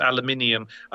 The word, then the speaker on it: aluminium: Oh, aluminum. There's another one, of course. Aluminium. That's a different thing entirely. Of course, we just pronounce it differently.